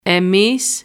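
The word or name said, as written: εμείς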